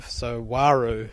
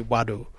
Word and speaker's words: Waru: So Waru actually means crow in my language. So, if we was to say his name in a Western way, in tradition, it would be Wadu.